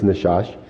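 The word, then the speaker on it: nachash: And so the Hebrew word for serpent in this particular passage, as I looked it up, was Nashash. So I always have trouble with these words pronouncing sometimes, but it's Nashash.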